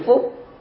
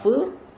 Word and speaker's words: phúc: Chúng ta có thể dịch nói ra hai từ, cũng có thể đọc là phúc mà cũng có thể đọc là phước, đều là chữ đó cả và đồng một nghĩa như nhau.